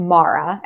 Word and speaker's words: MARA: my last name is um, but it's pronounced mara as a brand and mara means sea in